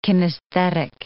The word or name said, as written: kinesthetic